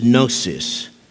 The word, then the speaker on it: gnosis: Usually the word for knowledge is gnosis, G-N-O-S-I-S, the G is silent, unless you, like I did, learn Greek in the South, and then it's gnosis, because y'all got to put all them letters in there.